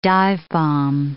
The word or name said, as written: dive-bomb